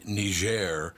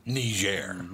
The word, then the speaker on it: Niger: Uh, since President Obama found out that the French pronounced the name of the country Niger, mm-hmm. now all of a sudden everybody on the news pronounce it Niger.